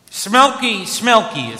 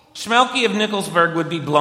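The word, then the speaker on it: Shmelke: Schmielke is probably a better way to pronounce it.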